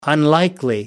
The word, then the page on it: unlikely: American English